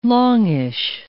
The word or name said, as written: longish